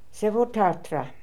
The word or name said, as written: Sevohtartra